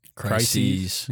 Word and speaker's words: crises: There's a lot of, how do you say crisis plurally? – Crises. – Yeah. – Crises.